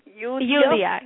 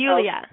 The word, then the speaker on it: Yuliya: Yuliya, yes. – Yuliya. – Yes, it's Yuliya.